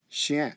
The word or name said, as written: chien